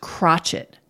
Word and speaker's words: crotchet: And in English, it comes to be pronounced crotchet.